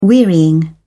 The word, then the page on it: wearying: British English